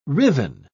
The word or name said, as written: riven